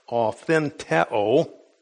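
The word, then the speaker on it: authentheo (αὐθεντέω): It's pronounced authentheo.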